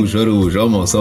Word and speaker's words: Araujo: Araujo, almost. – Araujo. So the O is silent.